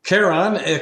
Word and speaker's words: Charon: It's like Charon or Charon. Charon, I don't know. – Charon, final. – Charon, correctly pronounced, too.